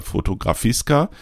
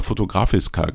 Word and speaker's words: Fotografiska: Die habe ich natürlich, die Zeit hatte ich mir genommen, anzuschauen in der Fotografiska. Bisher habe ich immer Fotografiska gesagt und ich weiß jetzt, dass ich das immer falsch ausgesprochen habe. Das wird Fotografiska ausgesprochen, Entschuldigung. So, ja, 15 Euro, das ist dann auch ein Wort, also auch vielleicht ein kleines bisschen teurer als die eine oder andere Ausstellung.